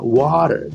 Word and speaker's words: water: あと、かかの有名な water ですね。これやっぱり t が全部ラに近い発音になるんですよね。